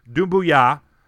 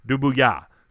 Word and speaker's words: Doumbouya: So think Stuart Scott with the booyah, emphasis on that last syllable. Go short and quick with booyah, doom boom, and then doom boo, and then go with the yah. So Doumbouya.